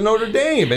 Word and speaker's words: Notre Dame: They call it Notre Dame and, right. – and not Dom and everything.